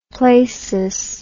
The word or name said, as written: plasis